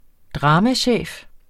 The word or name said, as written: dramachef